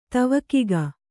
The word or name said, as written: ತವಕಿಗ